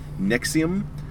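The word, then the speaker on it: NXIVM: broke her silence Thursday about her participation in, Jesus Christ, Nixon, the creepy self-group pronounced Nexium, whose leader has changed with running a sex slave cult in upstate New York.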